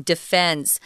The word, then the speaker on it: defense: But here, if you're defending yourself, then I would use the uh, the pronunciation Tom's been using, defense.